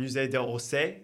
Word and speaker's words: Musée d'Orsay: Musée d'Orsay. I don't know how to pronounce it. – Oh, yeah. Musée d'Orsay.